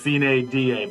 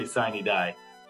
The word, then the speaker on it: sine die: So if you're a purist to the Latin phrase, it is sine die. But I hear a lot of people say sine die.